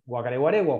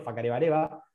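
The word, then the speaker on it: Whakarewarewa: que es el parque Huacareguaregua, o Facareguaregua, como le dicen ellos, Facarebareba, como lo pronuncian en Maurí, y otro más chiquitito, que es el Tepuya, pero todo lo bueno pasa ahí.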